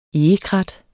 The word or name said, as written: egekrat